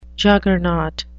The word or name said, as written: juggernaut